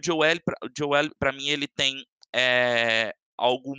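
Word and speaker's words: Joey L: E o meu último fotógrafo se chama Joe L. O Joe se escreve J-O-E-Y, Joe L.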